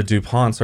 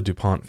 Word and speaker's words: DuPont: But it's spelled D-U-P-O-N-T. And in English, the second syllable is accented because it's French.